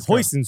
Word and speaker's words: Huijsen: Yeah, Dean Huijsen, who, I mean, I hope I'm pronouncing his name correctly. I say it like Huijsen sauce.